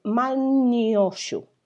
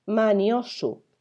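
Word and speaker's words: Man'yōshū: Estos conceptos se vieron por primera vez, el concepto de Wabi se vio por primera vez escrito, de forma escrita en el Manioshu. Espera, que no lo he dicho bien. Manioshu, que es la colección de 10.000 hojas.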